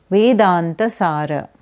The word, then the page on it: Vedantasara: Sanskrit